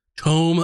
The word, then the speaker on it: tome: And we are here for the third installment of Caches and Cantrips, Tome of Knowledge. I made sure to say it correctly that time. It's not tomb, it's tome.